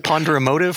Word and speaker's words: ponderomotive: Can you say that word in your paper that I've read multiple times, but I'm not positive how to pronounce it? – The ponderomotive force? – Yeah, this. Yeah. That, yes. Ponderomotive. Yeah. Acoustic ponderomotive force.